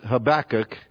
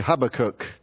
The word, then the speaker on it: Habakkuk: Now, the self-pronouncing Bibles pronounce it Habakkuk, but then every once in a while you'll hear somebody come along and say Habakkuk. So you join one of those camps and pray about it and how the Lord leads you. That's where you can go. But the self-pronunciation in the original language would be Habakkuk.